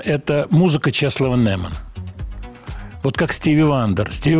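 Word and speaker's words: Czesław Niemen: По поводу Чеслова Немана, я вот так произношу его имя, так, как было принято.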